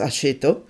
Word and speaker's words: Aceto: His surname, in Italian pronounced Aceto, means vinegar.